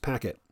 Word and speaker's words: PKT: And I should say Packet is capital P, capital K, capital T. And I've been reading it PKT Network until I heard it pronounced, I think, by Vishnu as Packet.